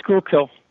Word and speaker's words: Schuylkill: Is it the Schuylkill? – It's Schuylkill.